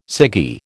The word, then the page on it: ciggy: American English